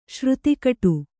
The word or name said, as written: ಶ್ರುತಿಕಟು